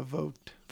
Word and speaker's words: Vogt: I don't know if I'm pronouncing his name right. V-O-G-T dash Roberts. – Yeah, Vogt. – Vogt. – Yeah. – Vogt Roberts, yeah.